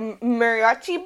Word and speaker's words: mariachi: Like, so this is interesting that in a Western movie, they had a combination of like a mariachi band. Is that how you properly pronounce it? – Yes.